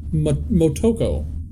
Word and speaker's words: Motoko: Marcuto. – Here, I got it up. Motoko.